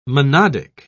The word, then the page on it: monodic: British English